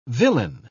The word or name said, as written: villein